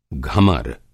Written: घमर

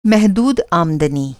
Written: महदूद

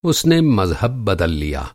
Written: मज़हब

